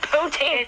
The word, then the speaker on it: poutine: Oh, I love how he pronounces poutine. Poutine. It's poutine.